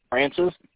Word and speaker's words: Francis: M-C, capital S-O-R-L-E-Y. – O-L? – O-R-L-E-Y. – L-E-Y. First name? – Francis, F-R-A-N-C-I-S.